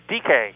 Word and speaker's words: DK: This is DK.